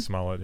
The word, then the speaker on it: Smollett: This is the Jussie Smollett. Is that how you pronounce his last name? – Jussie Smollett, yeah.